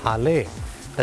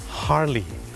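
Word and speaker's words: Harley: คนไทยออกเสียงว่าฮาร์เลย์แต่เจ้าของภาษานะครับไม่ได้ออกเสียงว่าฮาร์เลย์ครับเขาออกเสียงว่าฮาร์ลีฮาร์ลี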